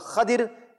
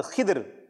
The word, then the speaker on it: Khidr: There are three ways of calling his name. One is called Khadir, the other is called Khidr and Khadr. So there are different ways of pronouncing this name, subhanallah.